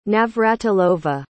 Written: navratilova

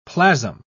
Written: plasm

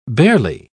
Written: barely

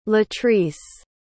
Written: latrice

